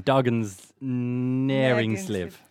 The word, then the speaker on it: Dagens Næringsliv: Dagens Næringsliv.